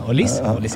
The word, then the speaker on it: Olise: Michael Olise som gjorde en fantastisk... – Hur visste du uttala? – Olis? – Ja, Olise.